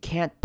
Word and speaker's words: can't: 好，看到下一句他说 You can't post that。好， can't post，有没有发现我两个字的 t 都省略掉了，你会念成 can't post that。好，所以两个 t 都可以省略，然后注意到 can't 的 a 一定要念成短母音 a 的声音， can't。